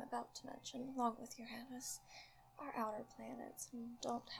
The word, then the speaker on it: Uranus: After Saturn, we get to Uranus, which is the correct pronunciation, because everybody decided to go with, your anus. Guess where I'd like to go in the entire galaxy? Your anus. So, like, human immaturity, as usually, ruined a perfectly good pronunciation, but it is funny, I have to admit.